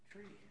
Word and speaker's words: Petri: It's only one T, so it should be a long E. – Like Petri.